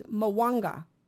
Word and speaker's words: Mwanga: Okay, and Mwanga or something, his name, I don't know how to say it. It's Mwanga, King Mwanga of Uganda.